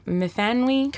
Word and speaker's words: Myfanwy: Myfanwy Jones? – Correct. – Myfanwy. Did I say it right? – Yep, Myfanwy.